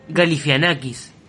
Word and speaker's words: Galifianakis: Le estamos diciendo mal el nombre. Galifianakis, no Galifianakis. – Pero lo pronuncian Galifianakis. Yo no dije Gali, dije Galifianakis. Lo pronuncian Galifianakis siempre.